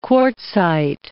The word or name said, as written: quartzite